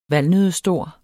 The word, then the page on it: valnøddestor: [ ˈvalnøðə- ]